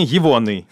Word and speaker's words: евоный: Ударение Евоный, а не...